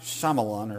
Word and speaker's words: Shyamalan: Night Shyamalan, or how you pronounce his name.